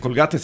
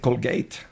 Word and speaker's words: Colgate: Bueno, no se dice Colgate, se dice Colgate.